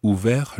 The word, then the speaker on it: ouvert: Ouvert, it's spelled O U V E R T, and that is the masculine form of that word.